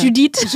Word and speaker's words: Judith: Manchmal fragen mich ja Leute, wie es wird denn mein Name ausgesprochen. Da sage ich Judith.